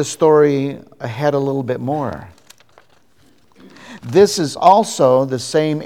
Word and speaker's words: mor: They made the connection because the root word for Moriah, Mount Moriah, the root word there in that word is mor, M-O-R, pronounced more. And the word more, Hebrew word more, is the Hebrew word for this gift, the gift of myrrh.